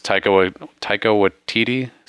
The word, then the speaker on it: Taika Waititi: It's Taika Waititi.